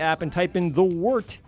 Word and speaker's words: Wort: Type in the Wort, spelled Wort, pronounced Wort, a podcast.